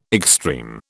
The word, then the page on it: extreme: British English